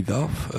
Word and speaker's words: Dove: vosotros ya sabéis lo que podéis esperar de este Hopeless Romantic de Bobby Dove, de Bobby Dove, es como el gel de baño, Bobby Dove, creo que es Dove, se pronuncia Dove,